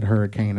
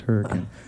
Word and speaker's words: Hurricane: He said Hurricane, and I said, "No, excuse me, sir, it's pronounced Hurricane."